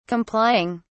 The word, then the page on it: complying: British English